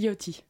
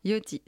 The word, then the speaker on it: Yachty: C'est Lil Yachty. On dit. – Yachty, ça marche.